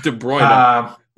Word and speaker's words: De Bruyne: Sterling and De Bruyne scored the other two. – De Bruyne? – Yeah, I definitely butchered that one. That was my bad. – De Bruyne.